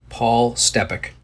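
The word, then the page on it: Paul Stepak: English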